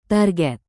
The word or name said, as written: target